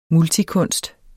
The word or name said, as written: multikunst